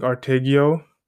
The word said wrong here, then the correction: Ortega: if I pronounce his name properly, or Ortega or something. Yeah, Ortega, sorry, Armando Ortega.